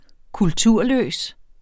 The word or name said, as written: kulturløs